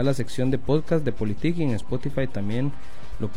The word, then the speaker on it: Politik: Eh, para los que tengan duda, la palabra eh, a la hora de buscarla se escribe P-O-L-I-T-I-K. Politic.